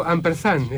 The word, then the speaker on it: Ampersan: Estamos con el dúo Ampersand, ¿es así? ¿Se pronuncia bien? – Está perfecto.